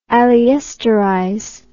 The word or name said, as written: aliesterase